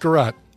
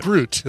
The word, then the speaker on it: Groot: It's pronounced Garot. – I think it's Garot. – It's pronounced Groot is what it is.